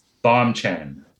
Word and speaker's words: Bombchan: It's actually a phonetically bastardized English version of my Korean name. So my Korean name is Bomchan. That's how my mom would say it.